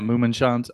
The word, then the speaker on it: Mummenschanz: If the Hotel Kabuki isn't available, maybe the Mummenschanz Hotel? Am I saying that right? – Yeah, Mummenschanz.